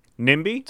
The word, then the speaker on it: NIMBY: Hey, is that acronym actually, is it pronounced at all? – NIMBY? – That's what I was wondering, if they called them NIMBYs or not.